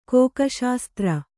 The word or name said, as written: ಕೋಕಶಾಸ್ತ್ರ